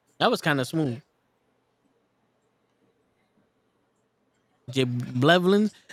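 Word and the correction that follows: J Balvin: Those sneakers, them shits is, I don't even know how to pronounce that, right? You don't read it. – Yeah, those are the Jay Balvins. – The who? – Jay Balvin. – Balvin. – Balvin, yeah. He's a reggaetonero.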